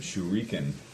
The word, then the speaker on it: Shuriken: Shriken? – Yeah, he's just cutting out the consonants. It says on Usagi Yojimbo, they pronounce it Shuriken.